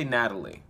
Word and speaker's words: Nathalie: Nathalie, I'm gonna say Natalie, but it's spelled Nathalie.